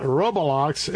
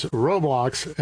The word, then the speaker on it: Roblox: And by the way, pardon me, is not Roblox, it's Roblox. And so I want to correct my pronunciation.